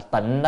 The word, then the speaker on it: tịnh: Chữ tinh là tịnh đó, có khi đọc âm tinh, có khi đọc tịnh. À, hồi trước thì các chư tổ hay Việt Nam hay đọc là tinh. À, bây giờ thì chúng ta thay đọc âm tịnh,